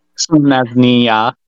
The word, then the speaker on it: Selesnya: I also have to point out that um, between the four of us, is the only one that really pronounces Selesnya without the emphasis on the nia. – it's, it's built into me. You should know this. Selesnya.